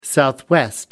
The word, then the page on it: southwest: American English